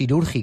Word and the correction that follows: Iraurgi: que he dicho Irurgi, es Iraurgi, de Azpeitia, de la localidad vasca.